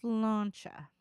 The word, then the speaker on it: Sláinte: But yeah, it's S-L-A-I-N-T-E. Do you know how to pronounce that? – S-L-A-I-N-T-E. Nope. – So it's Sláinte.